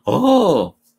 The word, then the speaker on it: awe: En un constante sentimiento como de, ah, ¡oh! Hay una palabra en inglés eh, que, se, que se deletrea A-W-E, awe, o se pronuncia. Oh,